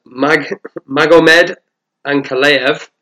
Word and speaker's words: Magomed Ankalaev: And Magomed Ankalaev against Ion Kutaleba.